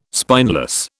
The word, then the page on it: spineless: British English